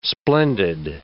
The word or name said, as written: splendid